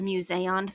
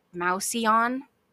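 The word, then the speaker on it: Mouseion: I'd really like to call it the museon throughout this episode because that's what they called it. But every time I see this word, because of the way it is spelled, I want to pronounce it mouseon, and that's just not correct.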